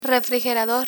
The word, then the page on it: refrigerador: Spanish